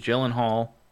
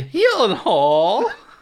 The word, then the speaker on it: Gyllenhaal: Just from the cast alone and Maggie Gyllenhaal, you know, directing. – Oh, you said it wrong. – Maggie Gyllenhaal. Sorry, was that better? – Much.